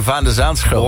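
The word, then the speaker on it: Van de Zandschulp: Ja, ik vind dat ze zijn naam ook goed uitspreken. Van der Zaanschulp.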